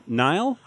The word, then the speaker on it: Niall: And Neil Horan? – Niall? – I looked it up. It's pronounced not like Nile. But he spells it weird. – Yeah, it's N-I-A-L-L.